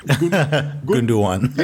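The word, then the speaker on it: Gündoğan: Gundogan.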